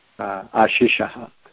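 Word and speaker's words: āśiṣā: ashishaha. The word ashisha, with a long A first, ashisha, the basic meaning is a blessing or a benediction.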